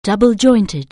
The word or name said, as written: double-jointed